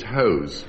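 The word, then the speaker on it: José: Once, as it's spelled, the second phonetically. On top it says Jose, and underneath it says hose.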